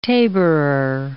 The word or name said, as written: taborer